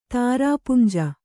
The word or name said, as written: ತಾರಾಪುಂಜ